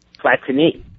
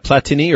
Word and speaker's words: Platini: Platini. – Yeah, how do we pronounce that? Platini or Platini? – You know, I hear it both ways. I guess Platini would be the French way, but yeah.